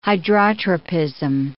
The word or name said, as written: hydrotropism